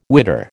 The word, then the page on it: witter: American English